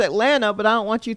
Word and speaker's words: Atlanta: I have to, when I'm talking to someone and I say Atlanta, I make sure to say the last T, even though that is not how it is pronounced in the city.